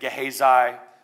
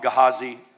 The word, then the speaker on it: Gehazi: You may say it Gehazi. We're going to call him Gehazi. That's how I pronounce it.